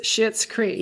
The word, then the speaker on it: Schitt's Creek: It's pronounced Schitt's Creek. – Yeah. But it's spelled C-S-H. – S-C-H-I-T-T apostrophe S.